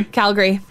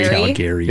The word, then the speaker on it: Calgary: Because how do you guys say Calgary? – Calgary. – And what's the bad pronunciation? – Calgary. Yeah, some people hit hard on the Gary.